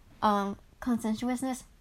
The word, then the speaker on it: conscientiousness: Openness, so how much a person can express themselves, whether it's creativity or emotions, consciousness, I don't know how to pronounce it, honestly. Conscientiousness, there we go.